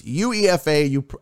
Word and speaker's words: UEFA: Oh, you pronounce it UEFA. Okay. So it's UEFA.